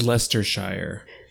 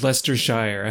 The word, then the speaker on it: Leicestershire: No, it's Leicestershire. Oh, but it's okay. L-E-I-C-E-S-T-E-R-S-H-I, like Worcestershire kind of. – Worcestershire. – It's Leicestershire.